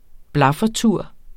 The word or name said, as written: blaffertur